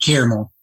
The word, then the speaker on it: caramel: I do not pronounce the second A. – No one either. – But I say it caramel. It's like rum caramel. – I just say just caramel.